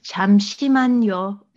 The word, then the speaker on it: 잠시만요: Was it 잠시만요? – 잠시만요. – Yeah. 잠시만요. – Uh, yeah, yeah. Um, I I learned it 잠깐만요, but uh, I think that's probably more uh, casual. So 잠시만요 is okay.